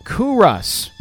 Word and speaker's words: Culross: I'm sorry, I said it wrong. Culross. She got the pronunciation here. Culross.